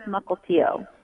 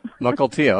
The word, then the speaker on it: Mukilteo: It's actually pronounced Mukilteo. – Mukilteo, okay.